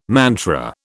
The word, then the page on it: mantra: British English